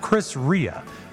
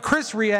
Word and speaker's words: Chris Rea: That is actually pronounced Chris Rhea. My apology to all the Rheas. I'm not going to make the joke. Ghana, Daya. But Chris Rhea, and the song is called Driving Home for Christmas.